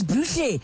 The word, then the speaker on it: Booshle: Alex Wonder, Neon Dreams, Bushle G, and Burn on 947. And just in case you're like, really, that's not how it's pronounced, it's Bushle. It's spelled B-O-O-S-H-L-E, just by the way.